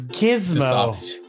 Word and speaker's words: Gizmo: It's Gizmo. – It's a soft G.